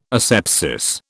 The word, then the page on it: asepsis: British English